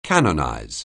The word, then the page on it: canonize: anglais